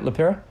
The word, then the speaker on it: LaPera: Did I pronounce that right, LaPera? – Yes, you did. – LaPera.